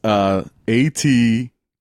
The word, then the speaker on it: Aterrados: All right, so the movie I watched is called Aterrados, and that's Aterrados.